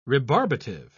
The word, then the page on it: rebarbative: British English